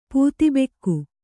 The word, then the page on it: ಪೂತಿಬೆಕ್ಕು: pūti bekku